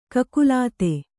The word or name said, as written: ಕಕಲಾತಿ